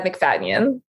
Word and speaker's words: McFadyen: McFadyen? How do you say it? – McFadyen. – McFadyen. Yeah.